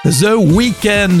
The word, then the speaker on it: The Weeknd: The Weeknd, la prononciation elle est bonne.